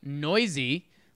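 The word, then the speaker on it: Neuse: Sheldon Noisy. Looks like it's spelled like noose.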